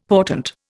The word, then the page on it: portent: Indian